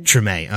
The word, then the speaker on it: Treme: Treme, okay, which was an HBO series, correct? – Yeah, about an actual neighborhood in the city of New Orleans that was actually called Treme.